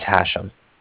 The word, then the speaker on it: Hashem: My name is Hashem. – Did you spell it for me? – Yes, H-A-S-H-E-M, as in mother at the end.